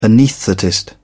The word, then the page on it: anaesthetist: British English